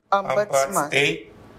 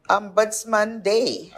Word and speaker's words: Ombudsman Day: This is, how you pronounce this? – Ombudsman Day. – Ombudsman Day.